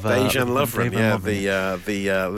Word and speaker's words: Dejan Lovren: But I never know how to say his first name. But Devran Lovren of Dejan Lovren, Liverpool centre back.